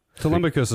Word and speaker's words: Telemachus: Telemachus is right. That's how you say it.